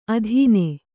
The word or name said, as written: ಅಧೀನೆ